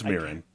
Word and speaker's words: Mirren: It's Mirren, but also.